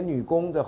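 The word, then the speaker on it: 女红: 啊，云记长呢写女工，这红要念成工，对不对啊？这个呃刺绣什么都会。三口这家里三口人养其十指供给啊，就是要靠他做手工。克昌从师修谱无缺，他去弟弟去念书，交学费也不用愁，就是因为姐姐做女工赚来的啊。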